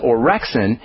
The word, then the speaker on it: orexin: It's a peptide called orexin, and that's spelled O-R-E-X-I-N, orexin.